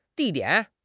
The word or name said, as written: di4 dian3